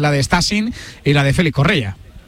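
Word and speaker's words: Stassin: Estamos hablando de un delantero, el nombre de Stasin, así se pronuncia, este jugador belga muy jovencito.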